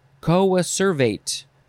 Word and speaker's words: coacervate: The words today were coacervate, that's how you say it, coach, coach dog, coach man, coach work, co-adapted, coadjutor, coadjutrix, coagulant, coagulase, coagulate.